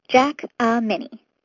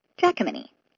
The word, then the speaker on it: Jackmany: It starts with Jack, like the boy who climbed the beanstalk. Then a soft A sound, and then Minnie, like Minnie Mouse. Jack-a-Minnie. Jackmany.